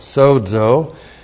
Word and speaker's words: sozo: S long O, Z long O, sozo.